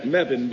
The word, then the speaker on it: Mebane: You pronounce that Mebane, by the way, and nothing causes a chuckle in a Mebaneite more than when people say, oh, you're from Mebane.